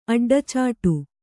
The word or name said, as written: ಅಡ್ಡಚಾಟು